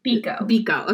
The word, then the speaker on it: Biko: And so dad comes in and says that they caught Stephen Biko.